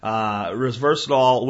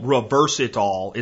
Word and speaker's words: resveratrol: And I'll tell you before I say anything else, I believe that this thing, resveratrol, which is, you know, being called reversitol is the way they pronounce it on purpose when they talk like reverse it all. That's not what it's really called. It's spelled R-E-S-V-E-R-A-T-R-O-L.